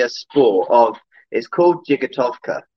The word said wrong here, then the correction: Jigitovka: okay? Jigitovka is how you say it. It's a Russian word, of course, because trick riding really originates from the Cossacks in Russia.